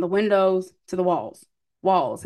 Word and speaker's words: Walz: His last name is Walls, not Waltz. So I'm not even gonna hold you. That is the only reason I know how to pronounce and say this man's name correctly, or else I would also say Waltz.